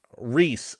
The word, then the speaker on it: Reese: Is it Rice or Rith Maddox? – Should be Reese, I believe. – Reese is how you say it. He has a Y on it, so spelled kind of uniquely.